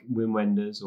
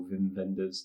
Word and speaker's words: Wim Wenders: So that's like Wim Wenders or Wim Wenders, depending on how you want to pronounce that.